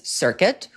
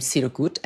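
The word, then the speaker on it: Cirkut: I mean, oh, and the producer of the year was Circuit, who I learned that's how you pronounce his name. I've been calling him Sirkut and Cricket, which are not his names.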